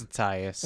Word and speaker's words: Attias: And directed by Dan Attis. I might be pronouncing his name wrong. I got Attias? – Yeah. Is it Attias? – I think it's Attias.